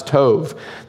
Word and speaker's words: tov: called tov, T-O-B, T-O-V, we would pronounce it tov.